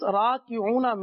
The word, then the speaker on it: راکعون: را الف زبر را کاف زیر کی را کی عین واو پیش راکعو نون زبرنا راکعون.